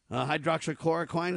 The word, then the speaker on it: hydroxychloroquine: Physicians urge governments to stop blocking access to hydroxychloroquine. Is that how you say it, Kurt? – That's correct. Yep, that's right.